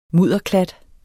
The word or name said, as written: mudderklat